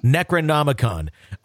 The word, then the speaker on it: Necronomicon: Necronomicon.